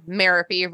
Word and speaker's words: Merope: When I Googled it, it was like, which I have to do a lot for this show, the UK pronunciation said Merope rhymes with therapy, which is something that she could have benefited from.